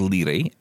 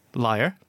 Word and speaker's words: Lire: I use an app called Lire, L-I-R-E, which is available for Mac and iPhone. – Is that Lire? Spell it again. – L-I-R-E. – Liar. Okay. – That's how it's pronounced.